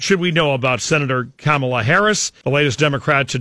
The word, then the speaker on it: Kamala: Her name is pronounced Kamala, comma with a la at the end, Kamala Harris, and she'll be quick to point it out and correct folks who don't get that right, very sensitive to that.